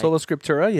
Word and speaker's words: Sola Scriptura: I don't know if I said that right. – Sola Scriptura, yeah.